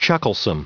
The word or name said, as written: chucklesome